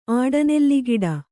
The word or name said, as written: ಆಡನೆಲ್ಲಿಗಿಡ